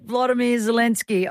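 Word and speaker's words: Volodymyr Zelensky: Hopefully my pronunciation is right, but Vladimir Zelensky. – That's good. That's it, Vladimir Zelensky.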